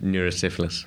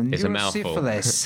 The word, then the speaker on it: neurosyphilis: And basically, you had neurosyphilis and then... – I love the way you say that. – How would you say it? – Neurosyphilis. – Ah, okay. Yeah. There you go. Neurosyphilis. Thank you, sir.